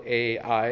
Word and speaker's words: Ai: Ai, which is spelled A-I and it's pronounced Ai.